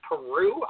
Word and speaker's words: Piru: It appears July 13th today, the body had been discovered floating in Lake Peru, I believe is how it's pronounced, P-I-R-U.